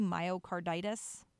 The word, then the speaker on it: myocarditis: First of all, am I saying that correctly? Myocarditis? Okay. – Yeah, you are, yes.